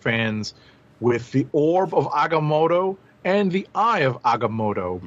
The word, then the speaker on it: Agamotto: So I'm glad that Marvel Studios has come to our aid and has shown us exactly how we're supposed to pronounce Agamotto, one of the Vishanti.